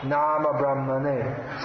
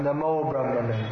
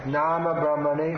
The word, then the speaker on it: Nama Brahmane: Nama Brahmane. Sometimes devotees chant Namo Brahmane, but that's a complete mispronunciation. Nama Brahmane.